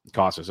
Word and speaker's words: Casas: And Tristan Casas, or Cases, I always mispronounce it, got called up. Casas, okay.